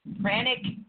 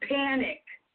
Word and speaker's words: pranic: Now we are going to deal with pranic healing, which is spelled P-R-A-N-I-C. – Yes. – Pranic. – So not panic.